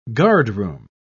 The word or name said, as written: guardroom